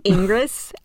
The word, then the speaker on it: Ingres: How do you spell that? – Ingres, I-N-G-R-E-S.